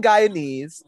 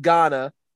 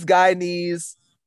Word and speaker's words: Guyanese: Being Guyanese, we have we have to explain to people a lot of times that it's not pronounced Ghana or Guinea.